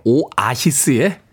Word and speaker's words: 오아시스: 오하시스 아니고요. 네, 오아시스의 whatever.